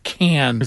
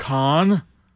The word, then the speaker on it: Cannes: It's pronounced Cannes. – Is it Cannes? – Cannes. – You rube. – Cannes.